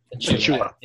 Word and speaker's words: Achiuwa: They lost Goran Dragic, Precious Achiuwa. Did I say that right? – Achiuwa.